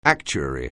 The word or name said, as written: actuary